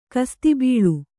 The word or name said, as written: ಖಾಸಾತಬೇಲಿ